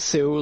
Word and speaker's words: Sioux: I think it's Sioux, like Sioux or Sioux.